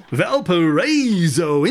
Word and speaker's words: Valparaiso: Valparaiso, Indiana. – Did that sound right? – Close. It's actually Valparaiso. – Valparaiso, Indiana.